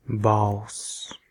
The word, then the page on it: voice: American English; /vɔɪs/